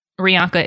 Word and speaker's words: Rianka: Rianca is spelled R-I-A-N-K-A.